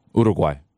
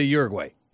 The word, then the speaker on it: Uruguay: And the other thing that impressed me was you properly pronounced Uruguay. – Uruguay. – Yeah, most people say Uruguay.